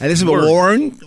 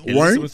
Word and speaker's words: Warren: Sí, Elizabeth, Warren. Warren.